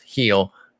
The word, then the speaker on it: Gil: You might be thinking Luis Gill, by the way, but it's pronounced heel, just mm-hmm.